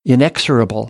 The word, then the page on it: inexorable: American English